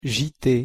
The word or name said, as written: JT